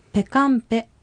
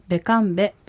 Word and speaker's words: ペカンペ: このベカンベという単語はテキストでは P で書かれていますが、アイヌ語では濁音と半濁音の区別がないので、ペカンペと発音してもベカンベと発音してもいいのです。